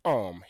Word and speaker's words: homme: E-S-T for E. Un, U-N. Then bon, B-O-N. Homme is the word for man in French. Homme, H-O-M-M-E. But the H there is silent. It's a silent H.